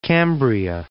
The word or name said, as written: Cambria